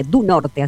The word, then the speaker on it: do Norte: Va a permitir conectar al Aeropuerto Internacional Rosario e Islas Malvinas con uno de los principales destinos turísticos del estado de Río Grande do Norte, du Norte, así se pronuncia.